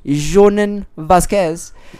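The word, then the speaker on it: Jhonen Vasquez: And I'm glad to say that creator, I'm going to butcher his name, Jonen Vasquez. – Hey, okay, well, not bad. – J-H-O-N-E-N.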